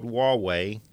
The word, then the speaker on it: Huawei: And it's from a company called Huawei. It's spelled H-U-A-W-E-I, but it's pronounced Huawei, I believe.